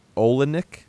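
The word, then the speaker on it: Oleynik: Okay, so it's Larissa Olenek, I guess is how you say it. – That sounds right.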